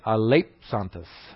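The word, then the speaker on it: aleipsantes: That little funny-looking thing there is actually a psi. It's pronounced like a PS. So it's aleiplantes.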